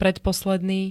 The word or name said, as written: predposledny